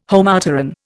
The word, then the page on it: Homopteran: Indian